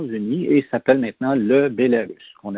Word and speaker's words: Bélarus: L'entrée principale est toujours Biélorussie et ils disent en biélorusse Bélarus, hein, parce que c'est comme ça que ça se prononce.